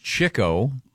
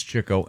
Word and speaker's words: Chico: It's pronounced Chico. Really? – I've never heard that one. – But it's Chico.